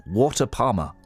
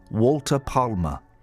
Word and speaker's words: Walter Palmer: Police are looking for dentist Walter Palmer. Both those names have got L's, got silent L's in. Walter Palmer. Walter Palmer.